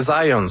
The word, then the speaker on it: Zajonc: c'est Zajonc. – Ouais? – Ouais, OK. J'ai-tu pas dit Zajonc? – Vous avez dit plus Zajonc, mais... – Ah, parce que j'ai prononcé un petit peu à l'anglais. J'ai prononcé mon polonais à l'anglais.